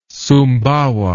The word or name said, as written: sumbawa